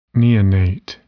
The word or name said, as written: neonate